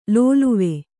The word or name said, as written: ಲೋಲುವೆ